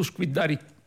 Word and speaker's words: squiddare: Questa in collaborazione con Anna Castiglia è proprio un tu squiddare. Ok, tu l'hai detto bene.